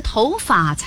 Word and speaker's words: 头发: 应该是头发才对。 – 所有在表示毛发的时候都读作发，比如长发、短发、剪发、染发、黑发、白发，唯独跟头搭在一起时读轻声，头发。